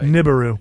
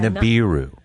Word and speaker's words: Nibiru: Say it right. – Nibiru. – Yeah, Nibiru. – Nibiru shock. – Get your Nibiru right.